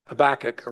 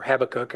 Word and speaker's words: Habakkuk: And that's a quote out of Habakkuk, or Habakkuk, however you want to pronounce it.